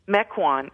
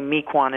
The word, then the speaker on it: Mequon: On Friday night, I'm going to be in Mequon. – Is that how you say it? See, I was going to say Mequon and make myself sound like a fool.